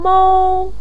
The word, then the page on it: mou1: pinyin